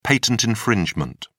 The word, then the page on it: patent infringement: British English